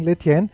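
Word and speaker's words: Lestienne: Gegen Letien, und das S spricht man nicht aus, wie ich gelernt habe, gegen Letien,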